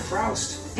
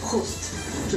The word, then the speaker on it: Proust: It's not Proust. – It's pronounced Proust.